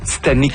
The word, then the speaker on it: Titanique: C'est important de préciser que vous dites Titanic parce que ça s'écrit T-I-T-A-N-I-Q-U-E.